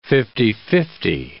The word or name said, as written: fifty-fifty